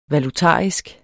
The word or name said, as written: valutarisk